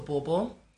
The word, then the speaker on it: Bobo: My name is Shamiso Bobo. That's spelled B-O-B-O.